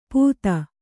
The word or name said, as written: ಪುತ್ತ